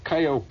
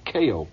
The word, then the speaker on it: Keio: And here is a tape that I recorded while I was sitting 14 stories above Tokyo in a fantastic hotel called Kaio Plaza, or Keio Plaza, it's more properly pronounced. It's spelled K-E-I-O.